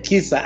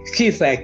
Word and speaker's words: P'Khisa: Mr. Wafula his, Khisa. I guess the P is silent. – Yeah, it is Khisa. Khisa. – Khisa, Khisa.